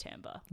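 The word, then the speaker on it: timbre: Spelt timber, pronounced tamber.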